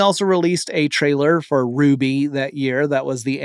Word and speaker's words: RWBY: And their initials create the title, R-W-B-Y, but it's pronounced Ruby.